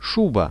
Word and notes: shuba: Russian

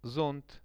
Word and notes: zont: Russian

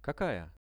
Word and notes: kakaja: Russian

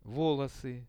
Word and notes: volosy: Russian

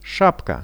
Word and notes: shapka: Russian

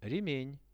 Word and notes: remen: Russian

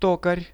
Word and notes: tokar: Russian

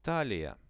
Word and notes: talia: Russian

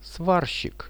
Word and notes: svarshik: Russian

